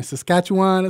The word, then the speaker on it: Saskatchewan: On this, I can't even say their name, Saskatchewan, that's how you say it, right? – Yeah, keep that dog in you, too.